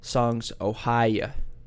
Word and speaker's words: Songs: Ohia: Songs Ohia is how I've been saying it, but after further deliberation last night, I think Eli corrected me of my wrong pronunciation. It's supposed to be Songs Ohia, like the guy, he's from Ohio, so I think you just say it with like a draw, Ohia, you know?